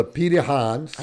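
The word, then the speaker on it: Pirahã: So let me introduce to you the tribe from Brazil, the Pirahans. – I've been waiting for this. – It's spelled actually P-I-R-A-H-A with a little U over the top S, right? But it's pronounced Pirahan, the Pirahans.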